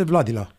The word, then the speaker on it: Vladila: Și numele firmei este Vladila. Așa se pronunță corect.